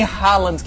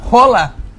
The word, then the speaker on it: Haaland: Porque não é Erling Haaland que fala o nome dele. – Como é que fala? – Rola. Erling Rola. Sabia disso? – Quem te disse isso? – Ele mesmo que fala, quando ele se apresenta, Erling Rola. Ele fala isso, não sou eu que estou inventando, não.